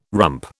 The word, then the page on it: rump: British English